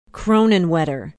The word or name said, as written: kronenwetter